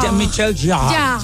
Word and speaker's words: Jean-Michel Jarre: Está mal, porque la E final en francés no se pronuncia. – Entonces, ya. Jean-Michel Jarre.